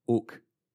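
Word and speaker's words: ook (οὐ): Also, two letters, O-U, pronounced ook.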